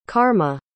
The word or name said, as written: carma